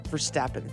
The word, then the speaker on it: Verstappen: Verstappen? – Verstappen.